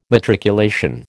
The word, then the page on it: matriculation: American English